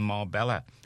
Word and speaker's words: Marbella: Echo special reports that Spanish police thought wanted criminal Philip Foster was in Marbella, you pronounce it, don't you?